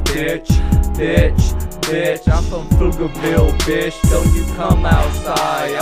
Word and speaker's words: Pflugerville: When you say Pflugerville, the P is silent.